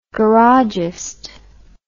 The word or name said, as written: garagist